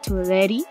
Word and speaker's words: Tuileries: Yeah, remember in French, they don't pronounce the last letter. – Yeah, I keep forgetting that. Tuileries.